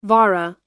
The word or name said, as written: vara